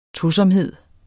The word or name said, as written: tosomhed